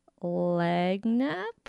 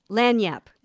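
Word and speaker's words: Lagniappe: Is it Lagnap? – close. Lagniap, and that's kind of how it's pronounced. Lagniap, I hope, because it's a Cajun word, and it means a little bit extra.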